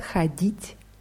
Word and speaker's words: ходить: Bei ходить ist die Betonung auf i. Мои родители не любят ходить в кино.